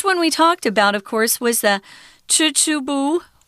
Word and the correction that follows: Chichibu: is that how you say it? – My uh, Japanese. Chichibu. Is... – Chichibu. Uh, that's how I learned it, I guess.